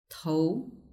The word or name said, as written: tou